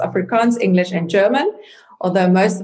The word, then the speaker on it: Afrikaans: So you grew up speaking Afrikaans.